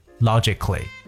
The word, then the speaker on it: logically: 包括大家在平时讲话的时候呢，我们要注重逻辑，所以有逻辑性的就是 logically。那么这三个单词大家仔细听一下：romantically、 musically、 logically。这里边 k 的发音都是由字母 c 来构成的，所以在这里边的字母 a 呢是不发任何音的。